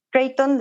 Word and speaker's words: Creighton: En mis redes es Create on Model MX, Creighton, de, la Universidad de Creighton, que es donde, donde se inició todos los estudios para generar este, este sistema. Se escribe C-R-E-I-G-H-T-O-N, Creighton.